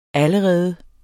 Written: allerede

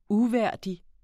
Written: uværdig

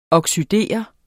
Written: oxidere

